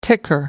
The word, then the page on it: ticker: American English